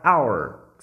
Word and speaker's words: hour: A palavra hour, que significa hora, hour, se escreve com H, H-O-U-R. Porém, esse H não tem som.